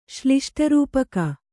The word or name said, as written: ಶ್ಲಿಷ್ಟರೂಪಕ